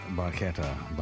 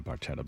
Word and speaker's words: Barchetta: Red Barchetta by Rush. I think the name of the song, they may say Barchetta, but it's pronounced Barchetta.